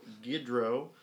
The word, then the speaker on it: Guidroz: And we are here with Cole Guidroz. – That's me. – That is how you say your last name, right? – Guidroz. – Okay.